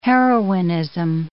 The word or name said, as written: heroinism